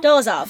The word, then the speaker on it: Dolezal: Dolezal.